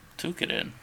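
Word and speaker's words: Toukiden: Shiren the Wanderer, and I'm gonna mispronounce this next one. Tokiden 2, or Night in the Woods. Which one do you want to hear about? – Tukiden. – Is that how you say it? Tukiden? – That's how I say it.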